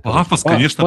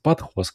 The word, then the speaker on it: Pathos: Пафос, конечно, пафос. – Патхос, как хотите, так и произносить.